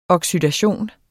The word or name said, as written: oxidation